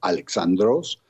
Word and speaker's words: Alexandros: En adición al nombre de este famoso monarca, que por cierto en griego se pronuncia Alexandros,